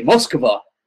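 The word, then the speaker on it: Moskva (Москва): Because in the U.S. we say Moscow, cow, and that's so far away from how they say it. I should say Moskva.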